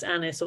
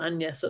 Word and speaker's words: Agnes: It would have been pronounced Annis or Agnes at the time.